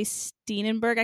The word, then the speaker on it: Steenburgen: But when I came to see your interview with Ted Danson and Mary Steenburgen, I can never say her name. – Steenburgen. – Steenburgen. Okay, Steenburgen.